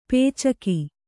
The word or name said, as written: ಪೈಚುಕೆ